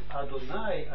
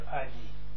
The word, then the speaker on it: Adni: Zo wordt het niet Adonai uitgesproken, maar Adni.